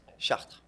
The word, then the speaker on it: Chartres: Chartres. – Chartres, okay. – Yeah, so you don't pronounce the E and the S at the end.